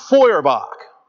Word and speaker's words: Feuerbach: There was a social philosopher in the middle of the 19th century named Ludwig Feuerbach. It's not spelled that way, but that's pronounced that way. He's German. You got to watch out sometimes the way Germans pronounce their words.